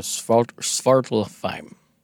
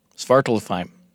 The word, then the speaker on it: Svartalfheim: And they live in the underground world of Svartalfheim. Svartalfheim. – Could you spell it? – S-V-A-R-T-A-L-F-H-E-I-M.